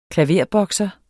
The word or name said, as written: klaverbokser